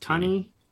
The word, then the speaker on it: Thuney: Joe, is it Joe Thuney? – Yeah, it's Thuney.